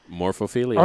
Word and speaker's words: morphophilia: Morphophilia? Is that pronounced that right? What does that look like? Freckles. – Morphophilia. – Okay, I pronounced that correct.